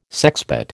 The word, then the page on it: sexpot: American English